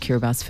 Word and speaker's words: Kiribati: Kiribati is actually spelled Kiribati, it's B A B A T I on the end, but you pronounce it Kiribati.